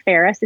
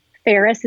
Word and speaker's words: Ferris: So, but if you reach out to either Twitter or Instagram, my handle is Paula Ferris. It's the same for both Twitter and Instagram, my first name and last name. Ferris is spelled just like Paris, the city, but with an F like Frank.